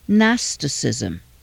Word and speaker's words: Gnosticism: Because the emphasis was on special knowledge or gnosis, it became known as Gnosticism. Again, the G is silent.